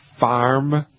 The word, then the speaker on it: Pharm: Now, there is something called Farm Solutions, and it's spelled P-H-A-R-M, farm, like pharmaceutical, Farm Solution.